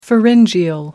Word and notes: pharyngeal: American English

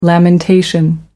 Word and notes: lamentation: American English

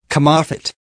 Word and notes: come off it: American English

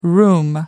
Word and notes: room: American English

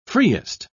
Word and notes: freest: British English

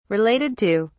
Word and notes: related to: British English

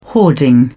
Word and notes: hoarding: British English

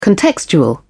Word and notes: contextual: British English

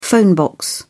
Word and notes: phone box: British English

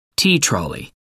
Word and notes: tea trolley: American English

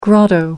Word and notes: grotto: American English

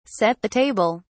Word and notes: set (the table): British English

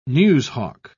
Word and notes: newshawk: British English

